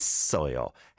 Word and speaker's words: 했어요: 했어요. 했어요. The tip I'd give you is leaving the double 상시옷 on the first syllable. 했어요. 했어요. Of course, it does blend into the R sound as well.